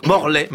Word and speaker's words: Morlaix: Mais oui, les policiers de Morlaix ont reçu une carte postale les remerciant d'une Morlaix, me dit-on dans l'oreille. Attention, évidemment, sûr. – On ne prononce pas de X, ça vaut autant pour moi.